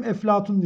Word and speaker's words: Eflatun: Yunanca'dan, Latinceden vesaire bazı isimleri Araplar telaffuz ederken P olmadığı için onu F'ye çeviriyorlar. Bizde o yüzden iki söylenişi vardır. Hem Platon diyenleri vardır hem Eflatun diyenler vardır.